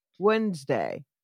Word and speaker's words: Wednesday: You don't say that first D. When Wednesday, right? – Yeah.